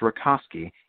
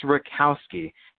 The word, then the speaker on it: Rakowski: His name looks like it should be pronounced Rakowski. It's Rakowski. Keep an eye on him.